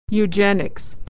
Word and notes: eugenics: /y/

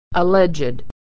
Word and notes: alleged: ed

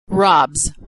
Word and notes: robs: ending z

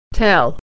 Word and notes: tell: e

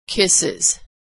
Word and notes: kisses: Iz

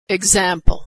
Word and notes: example: g

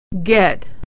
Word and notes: get: e

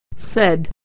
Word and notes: said: e

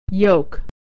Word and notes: yoke: /y/

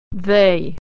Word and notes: they: e-ey

